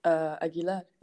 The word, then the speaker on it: Aguilar: On Instagram, Kira, my name, X, Aguilar, A-G-U-I-L-A-R. – Okay. And Kira K-I-R-A. – Is... K-I-R-A. X, mm-hmm. Aguilar, A-G-U...